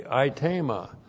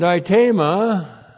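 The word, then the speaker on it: aitema (αἴτημα): A-I-T, long E-M-A, aitema. And aitema means request.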